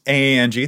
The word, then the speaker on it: Anje: Like for me, for example, in both of the the decks we just named, Gavi and Anje Falkenrath, I would say, excuse me, uh, Miss Angie, that's the official pronunciation.